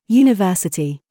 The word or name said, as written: TIGHT